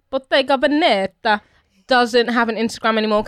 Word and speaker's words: Bottega Veneta: Bottega Veneta.